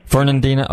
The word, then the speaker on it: Fernandina: Now, that's Fernandina Beach. Am I saying that right? – Fernandina.